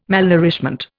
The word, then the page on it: malnourishment: Indian